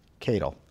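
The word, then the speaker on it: Katyal: It's K-A-T-Y-A-L. Cattell. That's how I pronounce it.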